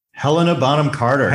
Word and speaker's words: Helena Bonham Carter: Helena Bonham Carter.